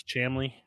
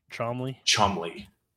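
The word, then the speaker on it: Cholmondeley: If I gave you the word that is spelt Cholmondely. – Okay. – I would then need to tell you that it's pronounced. – Tell me that's Chamley. Chomley.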